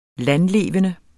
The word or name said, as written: landlevende